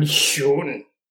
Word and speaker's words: schön: So I learned how to pronounce Schön and Schön because of the kid. The kid told me that, Otako, yeah, don't mind. This is how you say it, mm-hmm.